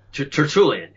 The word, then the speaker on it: Tertullian: Tertullian? – Tertullian, yeah.